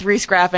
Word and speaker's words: Riesgraf: My bad, but it is Beth Riesgraf, and she is totally awesome and so beautiful.